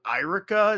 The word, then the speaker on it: Eirika: But the weird thing about the female lord is it's spelled like Irika. It's like E-I-R-I-K-A. It looks like if you ask your five-year-old to spell Erica, how they would spell it.